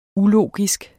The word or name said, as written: ulogisk